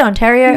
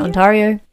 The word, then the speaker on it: Ontario: Ontario? Yeah. Ontario? – Yeah.